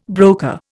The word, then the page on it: broker: Indian